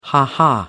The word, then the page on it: ha-ha: American English